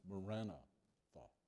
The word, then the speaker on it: Maranatha: Actually, it's two words. It's the word Marana and the word Tha. So it's actually pronounced Marana Tha.